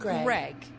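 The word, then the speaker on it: Graig: It's pronounced Greg. But it's spelled G R A I. I was like, his name is spelt like Craig, but with a G, and it's pronounced Greg.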